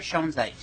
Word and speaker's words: Schoenzeit: Jennifer Schoenzeit. – And how do you spell that last name? S-C-H-O-E-N-Z-E-I-T.